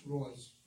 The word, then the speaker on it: Reuss: It's spelled R-E-U-S-S. And to me, that's Roos, but it's pronounced Royce by most people.